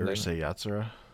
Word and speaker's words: Urusei Yatsura: Urusei Yatsura.